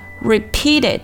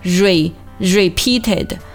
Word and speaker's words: repeated: 接下来我们看 repeated 这个单词啊，注意字母 r 发的是一个 r 的音，不要读成 repeated 啊，